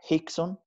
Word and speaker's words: Rickson: Eh, eh, por cierto, todos sus nombres son con R, como Rickson, pero pero en portugués eh, lo pronuncian como J, Hickson, mm.